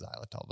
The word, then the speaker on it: xylitol: Does it have, in fact, xylitol listed as one of the, if that's how it's pronounced, I'm pretty sure it's xylitol,